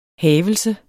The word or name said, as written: havelse